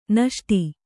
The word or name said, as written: ನಿಸುಟು